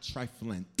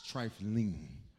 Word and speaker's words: trifling: Just for clarity's sake, for everyone, for the proper pronunciation of this word, it's not triflant. It's trifling. You know what this means?